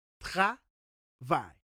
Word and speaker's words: travail: And in French, you say it like this travail.